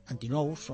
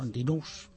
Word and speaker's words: Antinous: Sí, Antinous o Antinous, como queráis. Antinous se escribe.